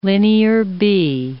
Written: Linear B

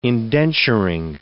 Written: indenturing

